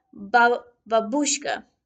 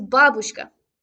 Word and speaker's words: babushka: Nearly every American that has tried to say this to me has said babushka, which is wrong. The correct way is babushka, with the stress on the first syllable.